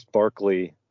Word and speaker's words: Berkeley: I think that this Barclay, which we in America would pronounce Berkeley, is the guy who the university is named after.